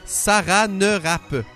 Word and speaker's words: Saranerape: Mais je vais le prononcer, je vais te l'épeler plutôt parce que c'est s'écrit pas comme ça se prononce. C'est écrit en phonétique là. S-A-R-A-N-E-R-A-P-E. Saran Rap.